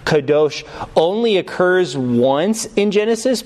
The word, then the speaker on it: kadosh: You would basically spell that K-A-D-O-S-H, K-A-D-O-S-H, kadosh. Or kodesh would be K-O-D-E-S-H. Kodesh, K-O-D-E-S-H.